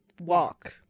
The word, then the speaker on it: walk: Do you pronounce the L in walk or is it silent? – Walk. – No L. – Walk. – I don't say the L. – I'm going to walk down the street. I'm not walk. – I'm going to say silent L.